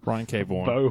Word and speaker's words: Vaughan: Vaughan? Oh, that's Vaughan, yeah. Vaughan. It's like Vince Vaughan. – Brian K. Vaughan.